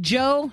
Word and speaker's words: Djo: And he's the lead singer of, I'm going to call it a band, Joe, spelt D-J-O. But it's pronounced Joe.